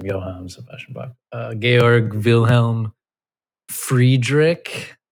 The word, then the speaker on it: Georg: First name, Georg. – Georg? That's one word. – G-E-O-R-G. That's how you pronounce George in German.